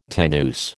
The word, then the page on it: tenuis: American English